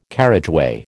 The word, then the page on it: carriageway: American English